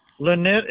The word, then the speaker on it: Lanett: Lynette is spelled L-A-N-E-T-T. – L-A-N-N-E-T-T. – No, no. No, just one N. L-A-N-E-T-T.